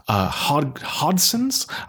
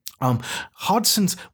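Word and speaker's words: Hodgson's: The main early brand of beer, Hodson's, I think it's pronounced. It has a G, and the G, I think, is silent. Hodson's was associated with the entire British Empire.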